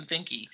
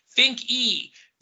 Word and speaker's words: Finke: and a Tim Finke. Finke, by the way. Finke. The E is pronounced.